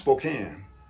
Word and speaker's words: Spokane: But Spokane, it's pronounced Spokane, but it's Spokane to me.